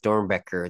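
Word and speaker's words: Doernbecher: And then I heard my my American buddy say it, and he's like, Dornbecker. And then I was like, oh! I was like, no, like, since he was, like, American, I knew that was the proper way to pronounce it, because there's no way an American's going to say, like, Dornbecker, looking at that.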